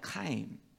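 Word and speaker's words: chaim (חיים): And then we have life, chaim.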